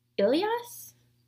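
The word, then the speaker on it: Ilyaas: and so his name was Ilias, is how I pronounce it, which is maybe not accurate, but it's I-L-Y-A-A-S.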